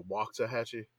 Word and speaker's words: Waxahachie: Are you making them go with the Waxahachie? – The Waxahachie is the proper pronunciation. – Okay. – Locals will say it's Waxahachie.